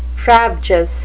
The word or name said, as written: frabjous